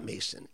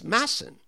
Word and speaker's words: Masson: It is not Masson. It's Masson.